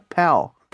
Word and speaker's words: Pal: não, mas é verdade, é, a pronúncia certa é pal. Tem que falar como se fosse outra letra. – Tem que usar a língua, né? – É, tem que usar a língua, exatamente. Não pode só simplesmente fechar igual o som de U.